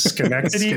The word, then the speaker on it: Schenectady: If you're going to be making up places, Schenectady, Schenectady.